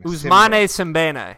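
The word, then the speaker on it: Ousmane Sembène: I don't think you pronounced that right. – No, I don't know. – What's the name of the movie? – It's Mandabi. The American translation, the English translation is The Money Order. – Mandabi. – How would you pronounce his name? – Ousmane Simbane.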